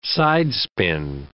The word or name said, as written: sidespin